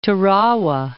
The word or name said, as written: Tarawa